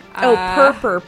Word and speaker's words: Purpur: Purper. P-U-R-P-U-R.